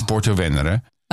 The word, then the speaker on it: Porto Venere: It's Porto Venere.